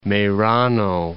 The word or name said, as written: Merano